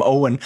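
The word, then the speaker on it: Eoghan: You know, my name is spelled E-O-G-H-A-N. I spent a lot of my life, Amy Adams actually helped me greatly in that terrible movie Leap Year, when she goes through a scene where she tries to pronounce my name Owen.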